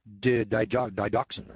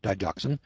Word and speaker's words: digoxin: For example, the FDA received an average of 82 reports each year about adverse reactions caused by the drug digoxin, or D-I-G-O-X-I-N, however you pronounce that, digoxin.